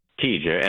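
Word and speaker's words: Teja: Well, it's T-E-J-A, and I pronounce TJ.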